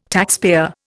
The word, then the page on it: taxpayer: Indian